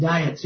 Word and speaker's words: diet: Just a little interesting bit of information regarding these diets. I think we pronounce it diets here, but I think the proper pronunciation is diet.